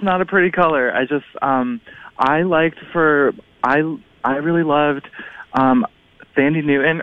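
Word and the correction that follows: Thandie Newton: or Tandy Newton is how her name is actually pronounced, from Westworld.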